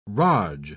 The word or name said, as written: raj